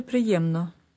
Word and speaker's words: приємно: Дуже приємно.